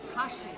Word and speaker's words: Kashi: Kashi. – Kashi. – Like the cereal.